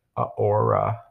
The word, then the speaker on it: aura: They have an aurora. Oh, did I say that right? Aurora. – Isn't that a Disney princess? – Oh, aura. – Aura. – An aura? – Aura. Like your vibe.